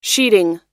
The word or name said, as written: sheading